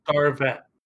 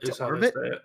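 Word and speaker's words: D'Arvit: specifically, how did they pronounce it in the audio version? Because I've always wondered that. – Darvet. Darvet. – It looks like it reads like Darvet.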